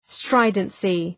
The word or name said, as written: stridency